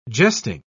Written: jesting

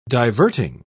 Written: diverting

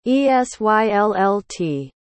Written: esyllt

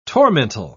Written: tormentil

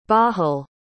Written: bahul

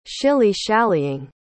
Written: shilly-shallying

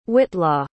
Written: whitlaw

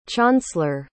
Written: chaunceler

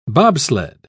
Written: bobsled

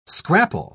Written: scrapple

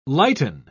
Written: lighten

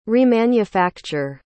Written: remanufacture

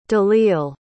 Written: dalil

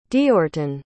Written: deortun

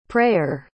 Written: preyer

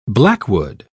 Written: blackwood

